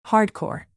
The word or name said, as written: hardcore